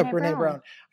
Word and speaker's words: Brené Brown: I heard, I'm probably going to butcher her name, but is it Brené Brown? – Oh, that's right. – Yeah, Brené Brown.